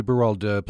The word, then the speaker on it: Berwald: Juli Berwald, pleasure to welcome you to Access Utah.